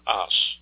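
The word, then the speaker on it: ās: Now, this comes from the word as. This is a long A, the A with a long mark over it, A, S, as.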